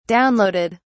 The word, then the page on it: downloaded: British English